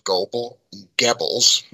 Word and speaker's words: Goebbels: And quite quickly, he got connected with Dr. Joseph Goebbels, that's how it's pronounced, and his propaganda ministry, and they were very, very good at making everybody think that Hitler was in charge.